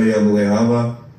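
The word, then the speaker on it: Iamaleava: Yamaleava. I don't know if I said that right. – Wow, that was a pretty solid pronunciation, I would say.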